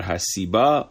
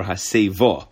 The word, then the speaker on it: hasiba: So we're going to pronounce the word haseba, but really according to it's the diktuk, it's either hasiba or haseva. Okay, but haseba we call it. That's the way the Oilim says this.